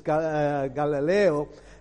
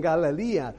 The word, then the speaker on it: Galilee: Sorry, is the pronunciation in English. In Spanish, it's Galileo. So I was speaking Spanglish there, saying Galilee. So they were from, how do you say it in English? Galilee, that's it.